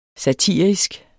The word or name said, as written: satirisk